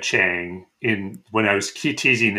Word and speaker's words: Chiang: It's Chang. Well, that's apparently, I'm just going honestly by how they pronounce it in the actual story. – Sure. – So I got to take that as the authority, because Weng-Chiang, I mean, he's supposed to be some Chinese god, I guess, but he didn't really exist.